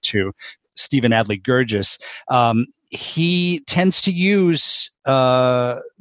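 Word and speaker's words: Guirgis: Gurgis. And by the way, that is the way to pronounce it, Gurgis, even though I just did it wrong for a moment.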